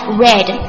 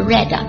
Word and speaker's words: read: read I love you 诶这里我干嘛把那个 read 读成了 read 因为它是过去式对不对动词的这个 read